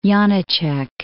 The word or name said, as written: Janacek